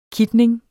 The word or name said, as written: kitning